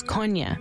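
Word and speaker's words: Kony: There are, it has to do with linguistic differences in southern Uganda, why people call him Coney. – The proper pronunciation is Konya.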